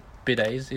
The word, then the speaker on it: bidets: So you know how, like, Japanese people, they use, like, bidets? Is that how you say it? – Yeah.